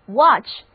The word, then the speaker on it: watch: 那我现在就敢打赌你们现在一定是在看电视，那这里的看就是要用 watch 这个单词，看电视就是 watch TV， watch W-A-T-C-H，意思就是观看、注视或者说照看。注意这里的字母 A 的读音是 O， watch，而词组 watch out 的意思和 look out 有点相似，表示当心。